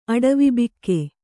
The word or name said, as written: ಅಡವಿಬೆಕ್ಕು